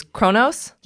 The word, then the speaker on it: Kronos: And then number 10 was Arrakis, 9 was Draculon, 8 was Thundera, 7, Cobol, 6 is Eternia and Etheria, 5 is Kronos. – Yes. – Thank you. – That's how it's pronounced in English. Just saying for you Trekkies out there. It's pronounced differently in Klingon.